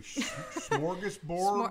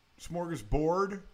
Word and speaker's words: smorgasbord: I don't, never know if I pronounce that correctly. Smorgasbord? – I think smorgasbord. – Smorgasbord? I always say it's a smorg. You're trying smorg. Like, I can't roll my R's when you have to roll your R's, but smorgasbord. I say Borg, and I think French. – It's bored. I don't even know. – Borg. I think it's with a G.